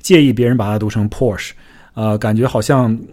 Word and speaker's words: Porsche: 我们也不能强求他叫 Porsche，所以他应该读成 Porsche，尾尾尾上还有一个 a 的音在后面。BMW 这个词就没什么好说的了。